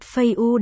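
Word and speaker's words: Phây u (废物): Ăn hại ở đây là từ phế vật phây u đọc là. Phây u, đọc gần giống với phai.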